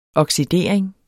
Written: OXIDERINGEN

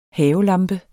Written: havelampe

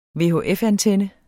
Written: VHF-antenne

